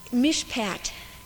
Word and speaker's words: mishpat: Justice, mishpat. Yeah, in English we'd spell it M-I-S-H-P-A-T.